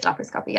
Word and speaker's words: laparoscopy: It's called laparoscopy. It is laparoscopy. I always said say that wrong, but I think that actually is right.